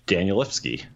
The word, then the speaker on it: Danielewski: Danielewski.